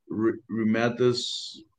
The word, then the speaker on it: remdesivir: Miriam is saying that she had issue with asthma, and she was given, and I can pronounce that, remdesivir.